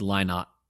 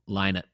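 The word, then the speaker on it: Lynott: Yeah, but I mean, when you think of Thin Lizzy, you think of Phil Linnett, and I'm sure we're saying that incorrectly. Linnett. I don't know if that's right. Hold on, let me look up. Yeah, you're right. It's Linnett. Linnett.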